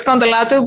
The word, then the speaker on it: Contolato: Yeah, it's well, it's Contolato, but it's Greek, so it's very hard to pronounce.